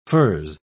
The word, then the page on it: furze: British English